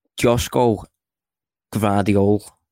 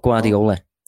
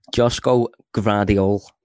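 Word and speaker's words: Joško Gvardiol: So again, bear with me with the pronunciation of this one. This is a great name, this. So Josko Gvardiol. Not Guardiola. Oh. Josko Gvardiol.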